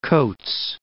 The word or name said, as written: Coats Land